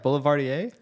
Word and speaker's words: Boulevardier: Boulevardier? – Yeah.